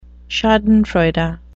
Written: schadenfreude

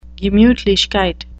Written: gemutlichkeit

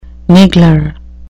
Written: niggler

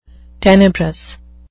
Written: tenebrous

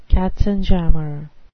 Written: katzenjammer

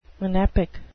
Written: monepic

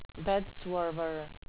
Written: bedswerver

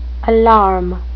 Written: alarum

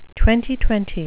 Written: twenty-twenty

